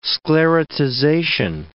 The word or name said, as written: sclerotization